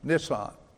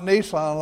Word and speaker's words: Nisan: I looked up several different Jewish men pronouncing that month, Nisan. It's not Nissan like a truck, and I found two different variations of it, but we're going to call it Nisan today.